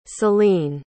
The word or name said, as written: seline